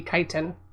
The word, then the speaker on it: chitin: And I always pronounce it chitin, and it could probably be chitin. – It's chitin. – Yeah, I've always heard it as chitin. I've never heard it. – It's chitin. That's what I'm gonna call it.